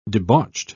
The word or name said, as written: debauched